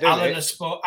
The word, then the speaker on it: Alanyaspor: Is it Alanyaspor? – Yeah.